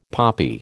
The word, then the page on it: poppy: American English